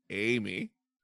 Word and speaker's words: Ami: Is it Amy? – It is. Okay. Amy. – And AMI. – Amy. Okay. AMI is Amy and also English.